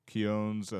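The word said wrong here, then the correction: Cuneo: and the Strassies. – Cuneos, yeah, there you go.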